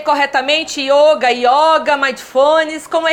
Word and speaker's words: mindfulness: O mindfulness é uma palavra em inglês, então a gente pronuncia exatamente como no inglês, que é mind de mente, fullness, ou seja, uma mente completa, uma atenção completa, atenção plena.